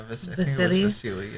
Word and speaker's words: Vasily: Vasily.